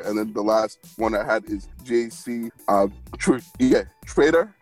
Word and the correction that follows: Tretter: Yeah, it's spelled T-R-E-T-T-E-R. So he... – Like Trader or Treader? – Treader, yeah, right. There you go, John. Thanks for the help on the pronunciation.